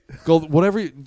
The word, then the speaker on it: Goldthwait: And also there's Bobcat Goldthwipe. Thwite is in it. Thwipe, Swite. – No Goldthwait.